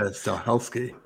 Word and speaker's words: Stahelski: Stahelski, yeah. It's like Stahelski. It's not Stahelski. It's Stahelski. Yeah. – Stahelski.